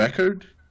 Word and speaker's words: Eckhardt: Geralmente, eu digo Eckhart. – Eckhart. – Tipo, é isso, exatamente, em queda. Aí fica mais fácil. – Ah, legal, então... – Ignora metade das consoantes no nome, tá beleza.